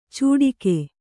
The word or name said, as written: ಚುಟಿಗೆ